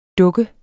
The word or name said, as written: dukke